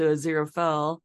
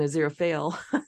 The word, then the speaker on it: Aziraphale: I didn't even attach it to Azira Fell, because I think I've been saying Azira Fail.